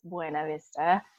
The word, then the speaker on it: Buena Vista: I grew up in a small little mountain town called Buena Vista, and you only call it Buena Vista if you're from there. Otherwise, it's Buena Vista. – That's so true. I thought it was called Buena Vista. – Yeah, we always heard that the town actually voted on the pronunciation of the name, like, I don't know, in the 1800s or something. So that's funny.